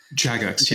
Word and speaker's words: Jagex: Jagex, yeah.